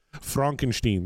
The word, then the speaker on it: Frankenstein: I actually heard from a Dutchman it's pronounced Frankenstein, but okay. we can semantics. – Is that Dutch or German? – It would be German in this regard, okay.